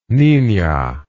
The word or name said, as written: Nina